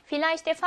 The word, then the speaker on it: Vielleicht: 자, 그러면 Vielleicht의 V는 F 발음을 냅니다.